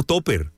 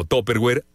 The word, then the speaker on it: Tupperware: ¿Por qué a los contenedores para almacenar comida se les conoce como topper? Tupperware es una marca de artículos para el hogar, incluyendo contenedores de plástico con tapa. En México es pronunciada comúnmente como Tupperware o solo Topper, o solamente Topper.